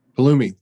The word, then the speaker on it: Pulumi: Pulumi. – Pulumi.